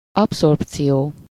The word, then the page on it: absorption: IPA: /ap.sɔʁp.sjɔ̃/; French